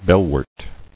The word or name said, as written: Bellwort